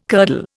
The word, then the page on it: curdle: Indian